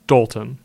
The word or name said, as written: Dolton